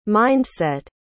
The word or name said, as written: mind-set